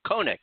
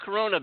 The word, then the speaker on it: Kona: Kona. K-O-N-A. – Oh, okay. Okay. Let me get it straight. I don't want them emailing us. It's a Kona. Spell it. – Kona beer. You're not Corona beer.